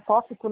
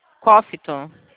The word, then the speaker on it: Cofico: A Cofico, como se fala? É Cófico, né? De Meridiano. Cófico. – Cófito.